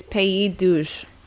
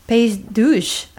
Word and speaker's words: Pays d'Auge: It looks like Pays. It's Pays d'Auge. – Pays d'Auge. Oh. – D'Auge. Yeah. – Pays d'Auge. Thank you.